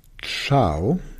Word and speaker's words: Ciao: Hier siehst du, wenn du dir die Podcast-PDFs runterlädst, Ciao wird mit C-I-A-O geschrieben. Das heißt, das C wird vor einem I und einem E, einem sogenannten hellen Vokal, wie ein Tsch ausgesprochen.